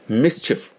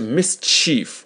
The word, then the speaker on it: mischief: Mischief, 注意发音，不是 mischief, 也不是 mischief, 是 mischief, 后面那个 C-H-I-E-F 啊，是短音 chief, chief, mischief, 啊，你说这帮小男孩啊，就喜欢搞恶作剧啊，叫 These boys are fond of mischief, be fond of, 就是喜欢嘛， be fond, F-O-N-D, Be fond of, 就是喜欢啊。